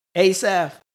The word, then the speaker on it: Asaph: How you pronounce it? Asaph. Yeah. Heman.